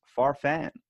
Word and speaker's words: Farfan: I'm here with Louie, Farfan.